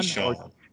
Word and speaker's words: Keyshawn: How do you say your name? Key or Sean? – Keyshawn.